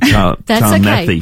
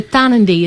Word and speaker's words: Tarnanthi: I'm saying it wrong too, aren't I? I've been saying Tarnanthi. – That's okay. It's really tricky. And it's important to... So Tarnanthi is the way you would say it.